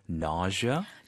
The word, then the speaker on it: nausea: Nausea, N-A-U-S-E-A, nausea. – Nausea. – Nausea. – Nausea. – Yeah. 그 S 발음이 je, 뭐 pleasure 할 때, vision 할 때.